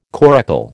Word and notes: coracle: American English

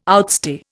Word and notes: outstay: Indian

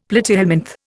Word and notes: platyhelminth: Indian

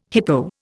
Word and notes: hippo: Indian

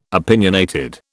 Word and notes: opinionated: British English

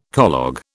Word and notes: collogue: British English

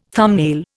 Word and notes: thumbnail: Indian

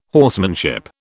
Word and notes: horsemanship: British English

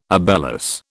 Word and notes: obelus: British English

